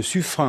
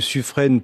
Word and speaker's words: Suffren: Soazic Kemener, alors les marins disent le Suffren, hein. Il y a 2 façons de le prononcer, plus communément le Suffren pour les civils que nous sommes.